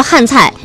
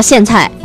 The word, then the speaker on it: 苋菜: 他说我老家湖南人和南方人叫汉菜，西北地区的人都叫苋菜啊。